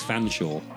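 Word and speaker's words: Featherstonehaugh: It's Fanshawe. It's spelt Featherstone Huff. It's pronounced Fanshawe.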